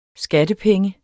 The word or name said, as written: skattepenge